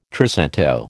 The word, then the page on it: trecento: American English